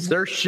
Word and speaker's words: Saoirse: Even had to learn how to pronounce her name. – Saoirse!